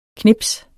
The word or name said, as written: knips